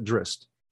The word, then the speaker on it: Drizzt: Well, I mean, listening to the audiobooks, they pronounce it Drizzt.